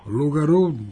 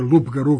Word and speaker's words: Lugaru: Lugaru, da ne bude Lupgaru, kao što neki kažu da je Lugaru, se izgovara i tako govorimo.